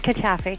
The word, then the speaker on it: Cattafi: It's Cattafi.